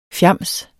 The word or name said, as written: fjams